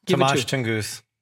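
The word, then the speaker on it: Tomas Tunguz: Tomas Tungus.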